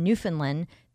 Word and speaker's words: Newfoundland: It takes you into the heart of a remarkable true story of 7,000 stranded passengers in the small town in Newfoundland that welcomed them.